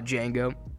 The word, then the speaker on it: Django: What about Django? Wow, that's a sick movie. The D is silent.